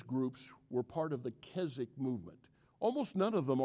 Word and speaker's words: Keswick: It's pronounced Keswick. It's a British word. So when you read the word, don't pronounce it like it looks.